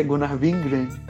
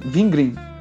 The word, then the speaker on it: Gunnar Vingren: sobre é, eu não consigo pronunciar o nome do, do Gunnar, Gunnar Ving, como é que é? Gunnar Vingren. Gunnar, é, eu também sou Gunnar Vingren. Green. Vin, Vingren. Isso. Isso.